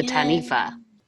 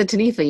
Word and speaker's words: Taniwha: And speaking of those Wikipedia lists, that's where I got this week's topic, the Taniwha, which is really fucking me up because it's spelled with a W and not an F, but there's an F sound.